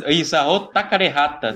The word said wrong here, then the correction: Takahata: Não, Isao Takahata.